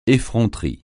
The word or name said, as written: effronterie